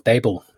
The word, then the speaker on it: Babel: You know, there's again, you know, I go back to the central banking model, which I identify with the Tower of Babel, Babel, as some people might pronounce it, and so on.